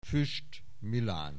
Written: Fuest Milan

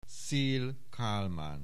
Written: Szell Kalman

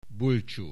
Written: Bulcsú

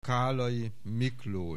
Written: Kállay, Miklós

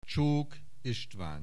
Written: Csok Istvan

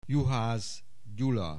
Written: Juhasz Gyula

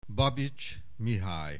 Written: Babits Mihaly

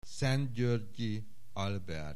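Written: Szent-Györgyi Albert

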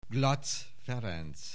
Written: Glatz Ferenc

